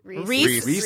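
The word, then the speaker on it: Rhys: It's Rise? It's like R. – Reese. – Is that Reese? – Okay.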